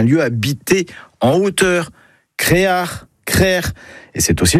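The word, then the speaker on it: creac'h: Le mot breton, c'est créar. Et ce mot, on le retrouve dans des hameaux ou des quartiers, même pour un phare. Et la bonne production est crère ou Créard. Créard, c'est une prononciation plus du Nord Finistère, mais on la trouve parfois ailleurs.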